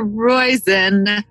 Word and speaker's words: Roizen: Oh my gosh, Dr. Roizen, which rhymes with poison.